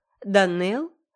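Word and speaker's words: Donnell: Итак, Данелл, ударение на второй слог, и Сен-Клер.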